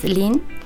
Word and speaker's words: Len: Bueno, se escribe L-E-N, uh-huh. Hay muchas maneras de pronunciarlo, es Lin.